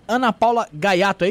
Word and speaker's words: Ana Paula Gaiato: Enfim, Seja muito bem-vinda, Ana Paula Gaiato, é isso mesmo que se pronuncia? Isso mesmo.